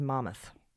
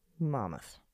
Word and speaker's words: Monmouth: wants us to know that the way a New Jerseyan pronounces Monmouth is Mammoth. Mammoth.